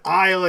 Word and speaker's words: isle: No problem hearing the word isle and you spell it I-S-L-E.